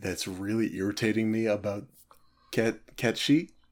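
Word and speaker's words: Cait Sith: It's written as Kate Sif, but it is pronounced Kat Shi.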